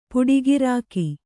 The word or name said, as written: ಪೋಟುಗಾರಿಕೆ